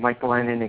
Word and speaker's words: Glennon: Yeah, it'll be a MRSA killing, but so for Tampa quarterback Mike Glenn, And Mike Glennon, excuse me, I almost got through a whole show without mispronouncing his almost.